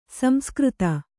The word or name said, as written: ಸಂಸ್ಕೃತಿ